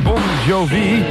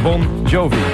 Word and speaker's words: Bon Jovi: Let op, hier komt een officiële uitspraak. Het is Bon Jovi. And you give love a bad name. – Yo! – Yo? – Yo, sir! – Yo, sir! Wat is in de cel? – Ja, de kenners die weten het natuurlijk uit Police Academy deel 2. Overigens, Bon Jovi komt uit New Jersey, uit de Verenigde Staten. En we hebben vanmorgen gebeld met de BBC. Die moeten dat soort dingen allemaal weten. Maar de officiële uitspraak is dus Bon Jovi.